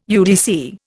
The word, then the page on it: UDC: Indian